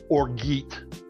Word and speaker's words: orgeat: And they have something called Orgeat, O-R-G-E-A-T. But that's not how it's pronounced. It's a French word. It's actually pronounced Orge.